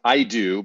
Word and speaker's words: AIDO: So one is pronounced IDO, but it's spelled AIDO.